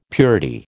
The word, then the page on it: purity: American English